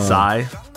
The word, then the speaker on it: Psy: Psy. PSY. Is that how you pronounce it? – Yeah, that's the guy.